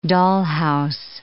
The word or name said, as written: dollhouse